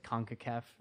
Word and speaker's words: CONCACAF: Webb was at FIFA and for a time also at CONCACAF. Is that how you pronounce it? – That's right.